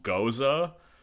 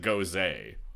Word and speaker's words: Gose: I know a guy who, there's a beer, it's spelled G-O-S-E, it's a type of beer, and it's pronounced Goza, but he pronounces it Goze,